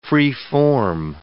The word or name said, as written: free-form